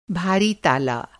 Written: ताला

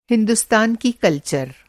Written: कल्चर

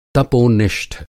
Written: तपोनिष्ठ

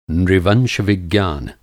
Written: नृवंश-विज्ञान

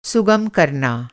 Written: सुगम

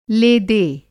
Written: ले दे

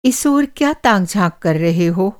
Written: ताक-झाँक

